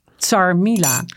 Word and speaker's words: chermoula: Ja, dus nee, ik, ging, ik zei dat ik Chermoula ging maken. Ze zei, nee, ze, zei het is Tjermula. – Zei nee, toen zei ik het is Tsarmila. Tsarmila. – Tsarmila, maar... – Ja, zo spreek je het uit.